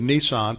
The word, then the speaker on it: Nisan: It could be late March, but it's probably on our calendar, April, and on their calendar, the month of Nisan, not the car. The month of Nisan, pronounced the same way, spelled a little different.